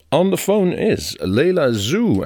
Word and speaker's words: Zoe: And uh, eh, I pronounce it right because the first time, a couple of uh, months ago, I. Uh, I picked out a song of your last CD and I said Zoe, which it shows if you write it, but it, it's called Zoo.